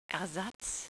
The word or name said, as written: Ersatz